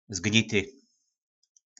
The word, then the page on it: Zgnity: Po našimu